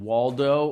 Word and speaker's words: Waldo: We know that he was, again, Waldo, or sometimes it's actually pronounced with a V sound on the front and an S sound on the end, which is more like Valdez.